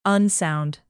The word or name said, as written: unsound